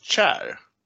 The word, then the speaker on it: Chair: Eh, bra spårlottning fick, hör nu på det här uttalet Marcus, om jag uttalar det rätt, nummer ett, Chair. – Mm, helt korrekt.